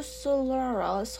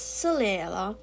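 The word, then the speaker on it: Solera: So that in the morning, you might say, I knew Celera's whole libretto from memory." I am sorry, it is Celera, not Celera in that English pronunciation.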